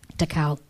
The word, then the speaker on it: Takao: Another revealing moment was our first trip together to Takao. – Did I say that right? – Mm-hmm. – Takao.